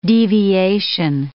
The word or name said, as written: deviation